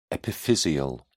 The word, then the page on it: epiphyseal: British English